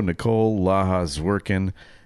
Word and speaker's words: Nicole La Ha Zwiercan: Please welcome to the show, Nicole La Ha Zwiercan.